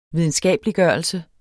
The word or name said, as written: videnskabeliggørelse